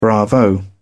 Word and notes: bravo: British English

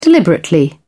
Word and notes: deliberately: British English

